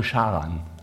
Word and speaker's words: Haran: Ja, und zwar gingen sie hier hoch nach Haran. Hebräisch ausgesprochen Charan. Also wenn du in der Bibel liest Haran und der dritte Sohn von Toran, der hieß ja auch Haran, aber das sind zwei verschiedene Wörter, das ist nur ein bisschen schlecht übersetzt in unserer Bibel.